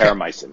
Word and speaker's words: Terramycin: So McKean launches Terra, and I may be mispronouncing it, Terramycin? – Exactly. Okay. Terramycin, exactly.